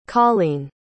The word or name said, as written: coleen